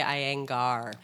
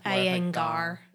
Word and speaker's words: Iyengar: First, let me try. Iyengar? – That's it. I think they say Iyengar. – Iyengar. – Yeah. – Yoga Institute. – The Iyengar.